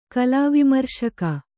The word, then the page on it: ಕಲಾವಿಮರ್ಶಕಿ: kalāvimarśaki